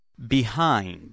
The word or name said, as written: behind